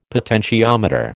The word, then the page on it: potentiometer: American English